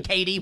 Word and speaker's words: Keady: Is that how you pronounce it? Katie? Like, Gene Katie? What did I say? Katie? Yeah. Gene Katie, yeah.